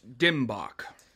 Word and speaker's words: DMBOK: And it is pronounced Dimbok. – Very well. Thank you for the clarification. – Now, Mr. Corporate, I want to look more closely here at the Dimbok wheel.